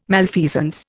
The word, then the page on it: malfeasance: Indian